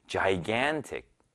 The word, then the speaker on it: gigantic: 어떤 분들은 gigantic 혹은 gigantic 뭐 이렇게 읽으시는 분들이 있는데요. 정확한 발음은 gigantic입니다. 한번 따라해 볼까요? gigantic.